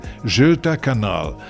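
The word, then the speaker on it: Göta kanal: Si pronuncia così in svedese, Göta Canal.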